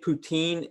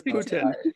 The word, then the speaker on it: poutine: Oh, did I pronounce it wrong? – Yeah. – It's poutine. Poutine. – That's everyone in the States says poutine, so I guess. – Yeah, just because that's well the English way to say it, but ever since I've been here, I can't say it like that.